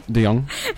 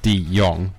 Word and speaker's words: De Jong: Deong. Deong. So I decided to do it really close together, like it was one word, but it's not one word. It's De Jong, but I did De Jong.